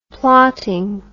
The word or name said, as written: plotting